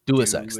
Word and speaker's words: Deus Ex: I've never learned how to say this. Des X and Tomb Raider. Deus Ex. Thank you.